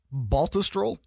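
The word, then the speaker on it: Baltusrol: Baltistrol? Am I saying that right? – Yes. You nailed it.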